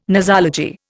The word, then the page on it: nosology: Indian